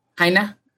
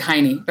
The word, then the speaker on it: Heine: Heine. – Yeah. It looks like Heine, but Heine.